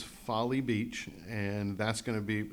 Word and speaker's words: Folly: Somebody says that they want to go to the beach, and they were thinking about going to Foley Beach. But the word is spelled F-O-L-L-Y. – Folly. – Folly Beach. – Folly Beach. – Just like Seward's Folly or any other word, anytime you've seen that word, it's pronounced the exact way it always has been, Folly Beach.